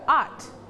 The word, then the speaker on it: art: Art. – Art. – Don't forget to pronounce the final sound.